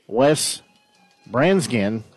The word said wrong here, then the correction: Bransgard: I think I butchered his last name. No, that's Bransgard. So Bransgard.